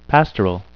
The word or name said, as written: pastoral